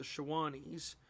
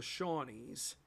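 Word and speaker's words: Shawnee: Those 12 tribes were the Wyandots, the Delawares, the Shawnees, or as the Indians would have pronounced it, the Shawanese, but from the Americanized version, it would have been the Shawnees, the Ottawas, Chippewas, Potawatomis, Miamis, Eel Rivers, Weyas, Kickapoos, Piankashoos, and Kaskaskias.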